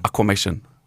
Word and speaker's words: aquamation: Aquamation.